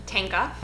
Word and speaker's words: Tanka: Tanka. – I wanted you to pronounce it, not me. – Yeah, it's hard all the way through.